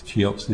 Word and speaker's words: Cheops: But again, Cheops, I think it is Cheops is the pronunciation.